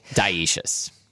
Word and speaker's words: dioecious: Dioecious. – Dioecious? – It's spelled with an O, but the O is silent. – Oh, you just say dioecious.